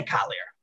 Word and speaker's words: Kalir: And just when he signs it without that yod, it's, so it's chaser, so it's still killer or kaler. We say kalir, but presumably he didn't just put the yod in there, and only when I write it with the yod do I pronounce it that way.